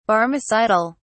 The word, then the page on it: barmecidal: British English